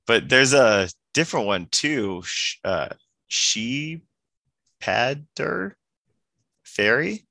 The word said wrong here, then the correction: Shepard: Oh, Shepherd.